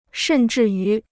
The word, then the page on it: 甚至于: shèn zhì yú